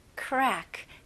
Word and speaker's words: crack: 네, 외래어로 이제 crack 이런 식으로 많이 발음하시잖아요. 뭐 크랙이 있다 이런 식으로. 근데 영어로는 이름절로 발음하셔야 됩니다. Crack, crack. 그래서 최대한 연결해 주시면 좋아요.